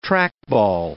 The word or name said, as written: trackball